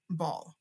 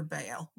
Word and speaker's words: Baal: the Jewish people kept worshiping Baal or Baal, whatever name you want to give it. Both pronunciations are fine. I'm just going to say Baal.